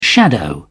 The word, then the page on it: shadow: British English